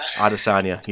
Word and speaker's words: Adesanya: You think Adesanya? How do you say his last name? – Adesanya.